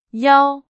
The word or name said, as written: 腰